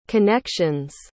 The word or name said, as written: connections